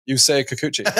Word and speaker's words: Yusei Kikuchi: Another really good Yusei Kikuchi start. Three innings. Two walks. You don't love to see that. Two strikeouts, no hits. – Can you say his name again? – Yusei Kikuchi.